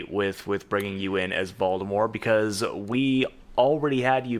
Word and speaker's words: Voldemort: No, yeah, Voldemort is the correct way to say it now.